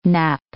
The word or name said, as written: knap